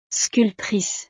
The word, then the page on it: sculptrice: frances